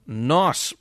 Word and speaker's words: Gnoss: First of all, new music will be released very soon, actually, by the band Nos. That's spelled G-N-O-S-S.